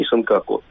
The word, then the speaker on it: Сенкаку: Во-первых, обратите внимание, что мы именуем острова в их японском произношении Сенкаку, то есть так, как обозначено на всех международных картах.